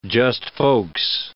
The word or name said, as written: just-folks